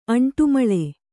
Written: ಅಂಟುಮಳೆ

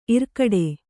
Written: ಇರ್ಕಡೆ

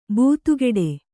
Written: ಬೆದೆಗೂಡು

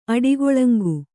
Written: ಅಡಿಗೊಳಂಗು

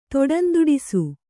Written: ದಡಂದಡಿಸು

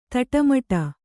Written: ತಿಟ್ಟಮಿಡು

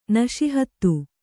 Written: ನಶಿಹತ್ತು

